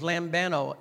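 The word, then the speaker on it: lambano (λαμβάνω): That Greek word is lambano, L-A-M-B-A-N-O, accent on the O.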